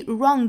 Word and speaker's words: wrong: 好，我们注意 wrong 这个词，我们仔细听视频里面这个演讲者的发音呢，把它发成了 wrong，尾部呢我们听到有一个 g 这样的声音带出来的 wrong。